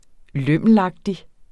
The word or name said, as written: lømmelagtig